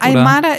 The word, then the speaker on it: Aymara: Aymara ist eigentlich eine Kultur, kann man sagen.